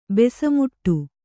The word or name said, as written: ಬೆಸಮುಟ್ಟು